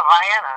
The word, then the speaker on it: Vienna: Vienna, Ohio. Am I correct? – Yeah, Vienna. That's how you say it, Vienna.